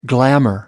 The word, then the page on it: glamor: American English